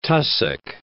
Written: tussock

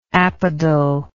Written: apodal

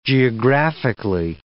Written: geographically

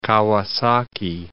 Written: Kawasaki

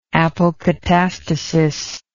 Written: apokatastasis

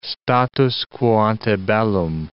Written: status quo ante bellum

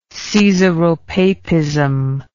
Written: caesaropapism

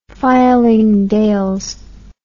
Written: fylingdales

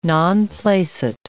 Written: non placet

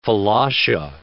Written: Falasha

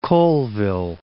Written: Colville

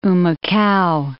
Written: Humacao

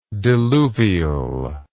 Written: diluvialist

